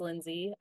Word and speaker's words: Lynsey: The best place is probably Instagram at Hey, it's Lindsay. My name is spelled LYNSEY. I know it's a weird, but Hey, it's Lindsay on Instagram.